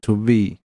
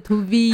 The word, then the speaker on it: Trouver: 那在法文里面的发音是 To V，那它的 V，再念一次 To V。